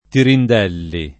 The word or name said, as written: Tirindelli